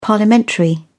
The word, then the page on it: parliamentary: British English